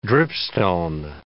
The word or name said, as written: dripstone